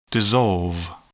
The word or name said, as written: dissolve